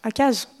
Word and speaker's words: Achaz: Akaz, est-ce qu'on prononce Achaz ou Akaz? Akaz, on va prononcer, déjà.